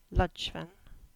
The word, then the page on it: Ludgvan: English